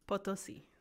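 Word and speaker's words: Potosí: Potosi. – Potosi.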